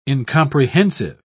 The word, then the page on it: incomprehensive: British English